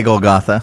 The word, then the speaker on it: Golgotha: Now, Golgotha, is it Golgotha or Golgotha? I've heard it pronounced both ways. How would you say it as the history? – I know what you mean either way. – Okay, all right. So Golgotha was... – I'd say Golgotha.